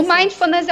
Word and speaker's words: mindfulness: O mindfulness é uma palavra em inglês, então a gente pronuncia exatamente como no inglês, que é mind de mente, fullness, ou seja, uma mente completa, uma atenção completa, atenção plena.